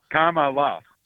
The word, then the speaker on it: Kamala: Like the, like, say comma. – Kamala. – Kamala. There you go.